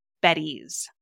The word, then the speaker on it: Beddy's: Taylor, I got to choose the main product to review, and today it's Beddy's. And that's spelled B-E-D-D-Y-S for those who are listening to this and might have heard buddies or something else like that.